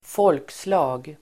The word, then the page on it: folkslag: [f'ål:ksla:g]